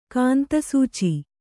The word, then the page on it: ಕಾಂತಸೂಚಿ: kāntasūci